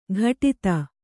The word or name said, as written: ಘಟಿತ